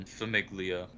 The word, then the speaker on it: famiglia: What's slopping famiglia? – All right. Today? – Famiglia. What is a famiglia? – It's family in Italian, but you pronounce the G.